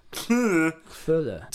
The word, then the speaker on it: Cthulhu: I believe it's pronounced Cthulhu.